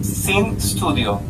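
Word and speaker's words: Syn Studio: Sí, se llama Sin Studio. Eh, eso se escribe S-Y-N Studio.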